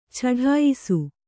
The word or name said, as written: ಚಡಾಯಿಸು